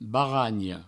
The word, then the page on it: baranha: baragno; Provençal